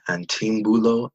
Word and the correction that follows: Anteambulo: That is Antiambulo, yeah, something like yes. That. – See, there we go. English educated right there. Antiambulo, that is spelled Anteambulo.